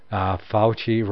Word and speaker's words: Fauci: Fauci rhymes with ouchie.